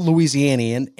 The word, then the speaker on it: Louisianian: Am I saying that right? Louisianian? – Yep. – Okay. That's how you say it. Yep. He's a proud Louisianian.